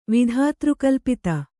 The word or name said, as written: ವಿಧಾತೃಕಲ್ಪಿತ